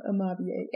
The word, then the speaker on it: Amabie: So today, I'm talking about Amavie. I'm pretty sure that's how you say it. – Amoeba? – No, Amabie. A-M-A-B-I-E.